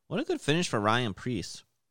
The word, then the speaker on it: Ryan Preece: I apologize, Ryan Preece.